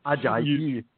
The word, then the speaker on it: Ajayi: Ajayi. – You have to say it properly. – Ajayi. – There you go.